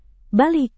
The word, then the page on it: balik: indonesian